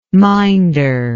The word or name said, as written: minder